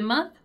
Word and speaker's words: month: We have here one word that gives us another great opportunity to practice the pronunciation of the TH, which is the word month, right? Month. Also notice that our vowel sound is more like month.